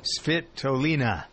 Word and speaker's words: Svitolina: Svitolina.